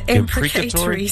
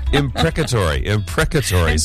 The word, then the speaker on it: imprecatory: And you called them those psalms, how did you pronounce that? – The imprecatory psalms. – Imprecatory. – How do you say it? – Imprecatory.